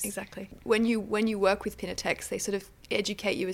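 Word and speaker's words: Piñatex: I'm going to say it wrong, Pinatex? – Yeah, perfect.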